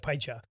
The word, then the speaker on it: Piech: My last name is spelled P-I-E-C-H. You look at it, Pie-cha.